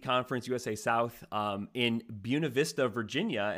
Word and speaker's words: Buena Vista: And I did double check this. This is the more ang- anglicized pronunciation of Buena Vista, just like Buena Vista College. It's not the Spanish pronunciation. So, uh, Southern Virginia and Buena Vista in Storm Lake, Iowa, we can stick with one pronunciation there.